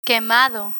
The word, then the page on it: quemado: Spanish